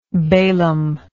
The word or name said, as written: balaamitical